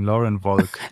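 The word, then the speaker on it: Lauren Wolk: Einfach Deutsch aussprechen. Lauren Volk.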